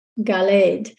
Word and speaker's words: Galed: Galed. Now, Galed is uh, spelled the same as Gilad, it's just voweled differently. You can look at the vowels and tell but it's spelled the same Galed Gilad.